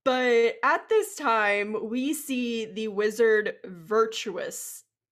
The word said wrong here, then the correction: Vitruvius: Did I say his name right? – Vitruvius.